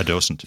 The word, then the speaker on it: docent: Docent? – A docent.